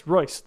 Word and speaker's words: Royce: It's Royce. Royce. That's how you say it.